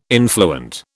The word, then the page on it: influent: British English